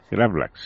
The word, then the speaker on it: gravlax: No sé si lo pronunciaré bien. Gravlax. – Gravlax, sí.